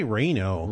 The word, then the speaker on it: Reno: I say Reno. – Reno sounds. – In fact, right. He sent an email to me and said, like Drano, except without the D.